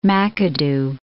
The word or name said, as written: McAdoo